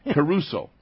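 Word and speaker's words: Caruso (κηρύσσω): The Greek word, I kind of like saying it because it sounds like a great artist, Caruso. That's how it's pronounced.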